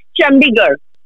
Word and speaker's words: Chandigarh: The next one is Chandigarh. – It's Chandigarh.